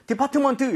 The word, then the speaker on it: department: 자, A의 강세가 너무 가버린 나머지 T가 발음이 될 이런 여유가 없어요. 그래서 department 이렇게 하시면 안 되고요. Department. Department 이런 식으로 하면 T가 아주 약화되거나 거의 안 들릴 겁니다.